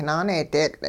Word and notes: naaneedeetL': hupa

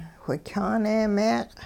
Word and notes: whikyaane-meeq': Hupa

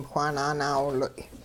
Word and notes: whaa nanaawiloy': hupa

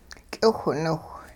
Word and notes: k'iwhniwh: hupa

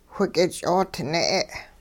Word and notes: whik'ich'oodne': hupa

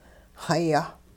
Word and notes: xa-yeh: hupa

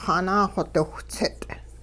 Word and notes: xanaaxodiwhtsit': hupa

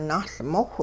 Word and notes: nahLmowh: Hupa

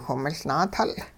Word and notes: whimiLnaatul: hupa

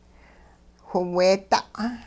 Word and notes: whiweeda': Hupa

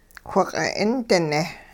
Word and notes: whiq'it-'indine: hupa

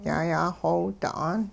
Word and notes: yayaaxoowda'aan: hupa

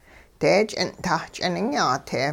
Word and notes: deech'ing'-tah ch'iningyaa-te: hupa

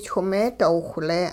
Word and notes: chwing-meedoowhle: hupa

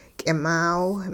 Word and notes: k'imaaw: hupa

